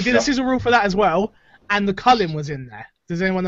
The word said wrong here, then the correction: The Culling: Yeah, it's called The Culling.